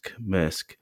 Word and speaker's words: Maersk: I think it's Maersk. – Maersk.